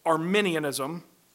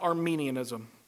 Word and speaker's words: Arminianism: The corollary to Calvinism is Arminianism, not Armenianism.